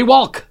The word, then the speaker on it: Walck: Buddy Walck.